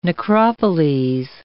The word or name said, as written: necropoles